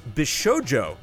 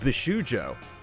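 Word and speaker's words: bishōjo: It's pronounced Bishojo, not Bishujo.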